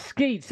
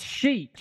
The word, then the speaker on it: Schaech: No one has corrected us yet, so it's spelled S-C-H-A-E-C-H. Skeech? – Johnny Boy. That's what we're calling. – Johnny S. – I don't think that's Skeech, that's Sheech. – Johnny Sheech Marin over here. Oh, what are you doing? – Yeah, so I'm saying Johnny Sheech.